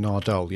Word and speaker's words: Nardole: Nardole, yeah.